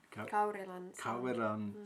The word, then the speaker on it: Kaurilan: Have you asked anybody the how do you say Kaurilan? – Kaurilan sauna.